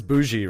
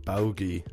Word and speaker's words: bougie: You know the one that the new one is bougie, right? Well, oh, when bougie. It when it's written, it looks like bogey. Like a hard G, yeah. like bogey. So that's how I'm reading it in my head before I ever heard anybody like say it out loud.